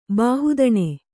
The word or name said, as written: ಬಾಹುದಣೆ